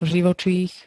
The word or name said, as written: živočích